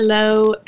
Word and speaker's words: Laux: So I'm on Instagram, Michaela Lowe XX, and Lowe is spelled L-A-U-X.